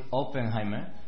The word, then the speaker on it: Oppenheimer: Oppenheimer. – Sí, bueno, esa es la pronunciación correcta, ¿no? Como decía, soy Oppenheimer, de la página Los Luthiers de la Web.